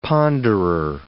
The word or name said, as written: ponderer